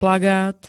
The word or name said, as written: plagát